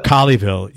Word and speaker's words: Colleyville: He's 23 years old and spent most of his childhood in Aledo and Cooleyville, Texas. Did I pronounce that right? Cooleyville? – Colleyville. – Colleyville, yeah.